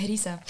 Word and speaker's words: Harissa: Ah, Harissa. Ja, spricht man genau. So aus? – Also eigentlich Harissa.